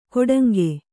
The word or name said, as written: ಕೊಡಂಗೆ